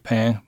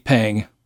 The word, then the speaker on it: pain: Most French people eat pain, manger du pain, but they eat pang in Saint-Gaudens.